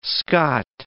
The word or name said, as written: Scot